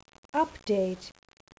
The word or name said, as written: update